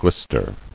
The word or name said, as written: Glistered